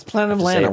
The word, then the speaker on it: Planet of Lana: No, it's Planet of Lana. We're going to do it. Lana Bashinsky is our friend, and therefore this is going to be pronounced Planet of Lana.